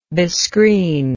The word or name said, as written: bescreen